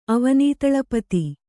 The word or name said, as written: ಅವನೀತಳಪತಿ